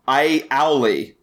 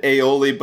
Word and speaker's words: aioli: Or as Chef would pronounce it, I owly. – Yeah. – It was something in Last Chance Kitchen where he said aioli but pronounced it a owly.